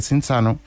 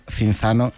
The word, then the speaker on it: Cinzano: Cinzano en 1940. Y este apellido, pronunciado italiano, quizá no nos suene tanto, pero si decimos Cinzano, nos va a sonar a todos muchísimo más.